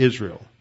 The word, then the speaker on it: Jezreel: It's spelled Jezreel, but they pronounce it Israel.